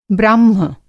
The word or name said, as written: ब्राह्म